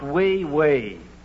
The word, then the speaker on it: oui, oui: That's pronounced wee-wee.